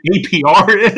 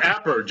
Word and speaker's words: APR: He doesn't realize what APR is. – It's pronounced Apper.